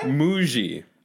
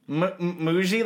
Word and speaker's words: Muji: Muji, like bougie? – Yeah, but with an M. – Muji? – So Muji. – Okay. – And people mispronounce it all the time because it's spelt like mushy.